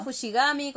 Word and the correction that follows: Fushiguro: como sea. – Fushiguro, así es, conoce a Fushiguro.